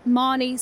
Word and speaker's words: Marnie: So if you want to follow that journey, my Instagram is at Marnie Swindles, M-A-R-N-I-E, S-W-I-N-D-E-L-S, E-L-L-S, I spelled my own name wrong there.